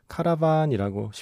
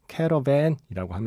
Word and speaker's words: Caravan: 카라반이라고 쉽게 발음을 하면 되고요. 예. 영화에 나오는 발음을 조금 비슷하게 말하려면 캐러밴이라고 하면 됩니다.